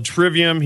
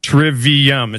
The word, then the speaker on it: trivium: What he does is he pronounces it how it would be pronounced in Latin. Well, we're not speaking about it in Latin. We're pronouncing it how we would talk about it in English, and it's called trivium. He can look it up in any dictionary anywhere. Trivium.